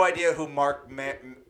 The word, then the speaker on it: Mero: Mero. Like bone marrow.